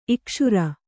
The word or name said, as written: ಇಕ್ಷುರ